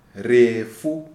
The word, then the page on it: Refu: Shona